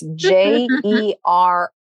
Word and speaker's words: Jeroslow: And in case you're wondering about how to spell that, that's JEROSLOW. So Jeroslow.